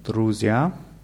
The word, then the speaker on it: друзья: Его друзья идут на концерт завтра вечером. – Да. Его друзья идут на концерт завтра вечером. Bei друзья ist die Betonung auf ja. Muss etwas stärker sein.